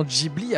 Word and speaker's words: Ghibli: Sa passion pour les avions se fait bien ressentir tout au long de sa filmographie et le nom Ghibli apparaît même sur l'avion de Porco Rosso. Et même si un italien le prononce avec un G dur, au Japon, il en est autrement et le G se prononce plutôt G.